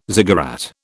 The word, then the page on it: ziggurat: British English